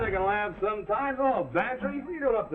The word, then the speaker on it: Bantreagh: Sounds like Cloud, but it's spelled M-A-C-L-E-O-D. Well, his is Bantry. Go on, spell it. Bantry, it sounds like pantry. – I ain't no gambler, Mac. – Well, get this, for free. It sounds like pantry, but it's spelled B-A-N-T-R-E-A-G-H.